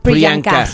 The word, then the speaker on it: Priyanka: Priyanka.